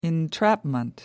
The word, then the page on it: entrapment: American English